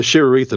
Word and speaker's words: Sheeriyth: I want to say the Sheeriyth imperative. Is that right? – That's pretty close to sheer, the Sheerith imperative. – The sheer wreath. So it's almost like two words, sheer and then wreath.